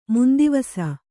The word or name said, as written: ಮಂದವಿಸು